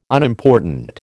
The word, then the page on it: Unimportant: American English